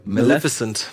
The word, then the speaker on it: Maleficent: Malefic mal mal Maleficent.